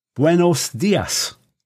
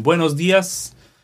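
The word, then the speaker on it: buenos días: Buenos dias. Good morning. Buenas tardes. Good afternoon. Buenas noches. Good evening. How was that? – Excellent. Excellent pronunciation. Buenos dias.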